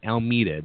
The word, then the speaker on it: Almeida: Let's welcome to the show from the band The Shape, Mr. Brian Almeida.